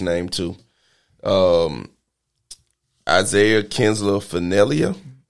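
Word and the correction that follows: Isiah Kiner-Falefa: Isaiah Kenner Falefa. – One more time for the people. – Isaiah Kenner Falefa.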